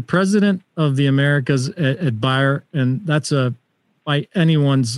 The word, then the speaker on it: Bayer: I think we pronounce it Bayer, but you would say Bayer.